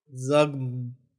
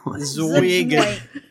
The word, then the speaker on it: Zuggtmoy: How do you think this is pronounced? Z-U-G-G-T-M-O-Y. – Zugtmoit? – Z-U-G-G-T. – Zugtmoit. – M... Oh, why? I think it's like Zugtmoy. – Zugtmoy. Zugtmoy.